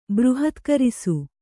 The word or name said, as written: ಬೃಹತ್ಕರಿಸು